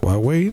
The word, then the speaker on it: Huawei: Ojo, se pronuncia Huawei, no, la H es muda, pero esa marca así se pronuncia, así que bueno, ya, ¿qué quieren que les diga?